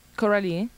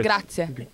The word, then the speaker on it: Grazie: Coralie. – No. I'm sorry. – Grazie.